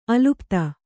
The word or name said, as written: ಅಲಿಪ್ತ